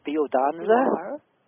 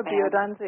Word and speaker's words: Biodanza: Biodanza. – You are. Biodanza is correct, although anglicized, people often say biodanza, but it does come from the Latin bios, which means life, and danza, dance.